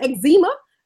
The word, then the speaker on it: eczema: Def Naturals, spelled D-E-F, Naturals. You need to holler at them. – Like the Zima water. – Not like the Zima water. No. I mispronounced it intentionally for the people in the back. Eczema. If you got the eczema, maybe the psoriasis.